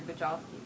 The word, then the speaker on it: Bujalski: She co-produced Mutual Appreciation and the second feature film by Andrew Bujalski. Am I butchering that? – No, it's great.